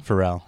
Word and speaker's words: Pharrell: How do you say the rapper's name? Is it Pharrell? Pharrell. – Pharrell. Yeah. PH.